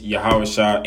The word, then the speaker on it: Yahweh: Um, it really doesn't really matter, but if you want to properly say his name, it's Yahweh in the Paleo Hebrew right?